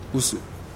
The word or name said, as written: usu